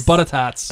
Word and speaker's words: butter tarts: Say it right. Butter tots.